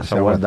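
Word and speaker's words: Azawad: Eta bai, Asawad, edo Asawata, edo silaba bat galtzen dugu. Asawad, Asawad da.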